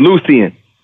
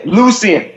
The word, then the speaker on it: Lucian: We used to say his name wrong. Pronounce it wrong. We just call him Lou. But it's Lucian. – Yeah, Lucian.